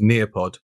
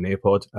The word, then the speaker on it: Nearpod: So those of you who don't know what Nearpod is, um, in fact, I better spell it because sometimes my pronunciation isn't great. So Near, N E A R, Pod, P O D.